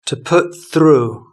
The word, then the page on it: to put through: British English